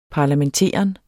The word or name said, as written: parlamenteren